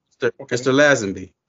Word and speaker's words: Lazenby: Mr. Lazenby, is that how you pronounce that? Lazenby. Jack Kent Cook told me once I didn't know how to pronounce my own name.